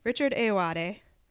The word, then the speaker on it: Richard Ayoade: Okay, Richard Ayoade.